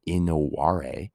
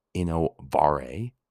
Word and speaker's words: innovare: Maybe you're innovative and it comes from the Latin innovare, innovare. But remember, in Latin, these are pronounced as W's, which means to renew or to change.